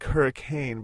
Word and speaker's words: Hurricane: It's spelled like hurricane, but for some reason everybody pronounces it Hurricane.